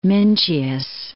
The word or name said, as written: Mincius